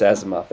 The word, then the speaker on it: Asimov: Yes, Asimov.